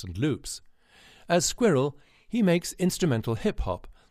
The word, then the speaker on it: Skwirl: By the way, Skwirl is pronounced like the furry little critter, but spelled phonetically S K W I R L.